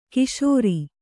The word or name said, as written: ಕಶೇರು